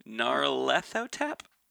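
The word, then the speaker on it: Nyarlathotep: People criticize how I pronounce it, so Gnarlethotep? – That's one of the approved versions to say his name.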